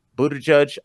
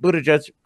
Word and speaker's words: Buttigieg: I'm very proud of that pronunciation. I feel like you didn't give me anything for that, but that's okay, Frank. I'm not digging for compliments. I'm just saying I said Pete Buttigieg perfectly. How do you know that's right? Did you look up the pronunciation? Frank, I do my research for the show. – Yeah, I looked okay. it up too.